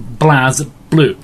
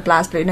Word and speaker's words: BlazBlue: So, BlazBlue, pronounced BlazBlue. – Because it's one word, BlazBlue. No Blaze, no E.